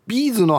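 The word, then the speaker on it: B'z: ビーズの発音は頭にイントネーション置かないですね頭だと手芸に使うビーズって感じがきえて気がしますビーズ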